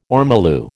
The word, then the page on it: ormolu: American English